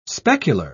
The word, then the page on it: specular: British English